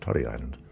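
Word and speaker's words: Tory Island: Torrey Island, but Francis O'Condoon told me years ago it should be pronounced Torrey Island.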